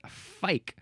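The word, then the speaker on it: fyke: Well, it looks like their word is pronounced fuik, spelled F-U-I-K. But at least the English, American English way is fike.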